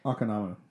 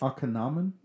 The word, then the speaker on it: Akhenaten: The Akhenaten or whatever. – Akhenaten. – Yeah, there we go. Yeah. Say that right for me, please. – Akhenaten? – Yeah, that's it.